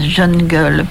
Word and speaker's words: jungle: paraît-il prononcer à l'anglaise, jungle.